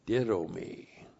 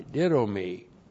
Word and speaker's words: didomi: Ditto me. D-I-D long O-M-I. Ditto me. A couple of things on this verb. First of all, didomi generally means to give, the verb to give or to grant or to bestow or to impart.